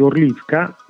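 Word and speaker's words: Horlivka: città di Orlivka, questa è la pronuncia ucraina, Gorlovka in russo, e sostanzialmente mi ha colpito molto, naturalmente, anche per il percorso accademico che ho fatto.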